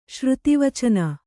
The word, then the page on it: ಶ್ರುತಿವಚನ: kannada